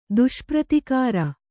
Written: ದುಷ್ಪ್ರತಿಕಾರ

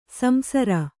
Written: ಸಂಸಾರಿ

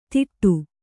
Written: ತಿಟ್ಟು

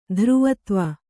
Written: ಧ್ರುವತ್ವ

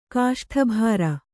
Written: ಕಾಷ್ಠಭಾರ

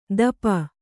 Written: ದಪ

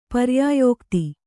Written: ಪರ್ಯಾಯೋಕ್ತಿ

